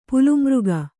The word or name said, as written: ಪಲುಮೃಗ